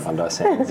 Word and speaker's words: Bondi Sands: He's the co-founder of Bondi Sands. – Bondi Sands, yeah.